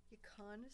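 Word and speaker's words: yacón: is yukon syrup. It's a natural sweetener. It grows from the yukon plant that's from the Andes Mountains. – I'm glad you pronounced it, because I was gonna call it yakin.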